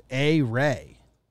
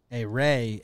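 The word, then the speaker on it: Ehire: Okay, according to Baseball Reference, it's A-Ray. A-Ray Adrianza.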